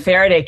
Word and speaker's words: Faraday: You talk about creating the, I might be saying this wrong, Faraday? – Yes, cage? The Faraday cage.